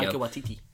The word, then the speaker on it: Taika Waititi: I think it's a very, very brave move for Marvel to give this to Taika Waititi. Or I'll probably butcher his name there, but there we go. – Taika Waititi.